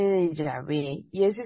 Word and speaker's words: Yahvé: Era el tetragramatón sagrado, que sería YHWH, que se pronunciaría como Yahvé.